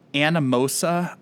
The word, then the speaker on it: Anamosa: 1891 in Anamosa, Iowa. I probably totally mispronounced that. – No, you did it. That's right.